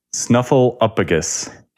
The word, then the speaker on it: Snuffleupagus: Snuffleupagus.